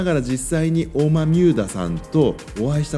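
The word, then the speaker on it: 大豆生田: 大きな豆の生えるあの生きるっていう生える田んぼと書いてオーマミューダオーマミューダって読む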